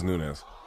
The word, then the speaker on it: Nunes: But is it pronounced Nunes? She came to fight. I give her a lot of credit. – I believe it. I think she's Brazilian, so I think it is Nunes.